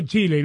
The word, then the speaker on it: Chile: Este, por lo menos dijo Chile y no dijo Chile, como pronuncian muchos angloparlantes.